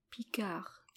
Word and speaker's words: Picard: But if you're going to be called Picard in French, you're not going to pronounce the D. – Really? – Picard.